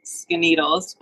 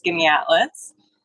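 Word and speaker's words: Skaneateles: So isn't, I was convinced it's pronounced Skinnedles, but apparently it's Skinnyatlas.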